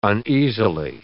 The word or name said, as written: uneasily